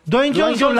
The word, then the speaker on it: Dwayne Johnson: Dwayne Johnson, La Roca.